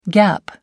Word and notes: gaap: American English

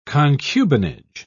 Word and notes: concubinage: British English

